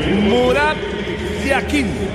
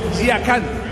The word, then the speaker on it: Yakin: En el suizo se pronuncia así: Murat Yakin. En turco, Yakan.